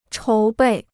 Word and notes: 筹备: chóu bèi

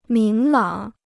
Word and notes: 明朗: míng lǎng

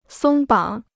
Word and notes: 松绑: sōng bǎng

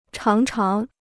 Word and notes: 常常: cháng cháng

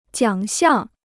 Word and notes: 奖项: jiǎng xiàng

